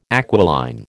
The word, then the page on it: aquiline: American English